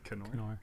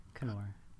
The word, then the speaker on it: Knorr: Actually pronounce Knorr. – Knorr. Okay. Let me try that again. – What? You didn't find the hard K? Knorr.